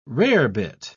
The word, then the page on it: rarebit: British English